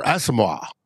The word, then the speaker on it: Asamoah: Next player, Brian Asamoah, or Asamoah, as he says it's pronounced, is an ascending talent at linebacker who showed some promise in limited roles last season.